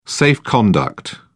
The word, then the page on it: safe-conduct: British English